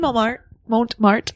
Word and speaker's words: Montmartre: And we did Montmartre.